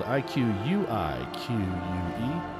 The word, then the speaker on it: Iquique: November 29th and 30th, the Bearded Villains of Chile have a fourth anniversary meet in Iquique, spelled I Q U I Q U E. I believe that's how I've been pronouncing it. Iquique.